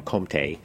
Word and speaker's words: Comté: One is Comté, which is pronounced with an N, but it's actually an M, Comté.